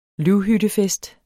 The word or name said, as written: løvhyttefest